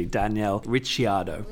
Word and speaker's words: Ricciardo: Danny Ric is fine as a shortened version, as a nickname, but it is officially Daniel Ricciardo. That's how you pronounce it, because it's an Italian name.